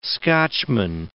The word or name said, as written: Scotchman